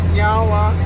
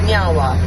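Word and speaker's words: Niá:wen: So, in our language, we say nyawa, which means thank you. – Nyawa. – Nyawa. – I said it right? – Yeah.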